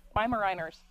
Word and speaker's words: Weimaraner: I'm from Pageland, South Carolina, so I may not be able to say this word right, but I think those Weimaraner dogs. Is that right? Is that how you say it? – Weimaraners.